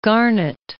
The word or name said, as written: Garnett